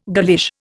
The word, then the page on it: girlish: Indian